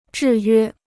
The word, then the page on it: 制约: zhì yuē